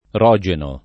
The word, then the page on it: Rogeno: italiana